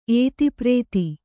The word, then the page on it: ಏತಿಪ್ರೇತಿ: ētiprēti